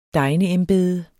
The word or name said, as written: degneembede